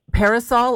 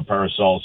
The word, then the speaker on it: Parasol: Am I pronouncing it correctly, Parasol, or is there a French pronunciation for this? – It could be both, you know, but you're doing it very well. It's Festival Parasol or Parasol.